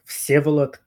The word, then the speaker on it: Vsevolod: So my name is pronounced Vsevolod Kovalevskij, and for the shortened version is Seva.